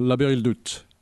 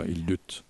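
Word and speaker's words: Aber-Ildut: L'Aber Hildut. Il faut bien prononcer le T, enfin, Hildut.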